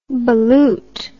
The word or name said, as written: ballute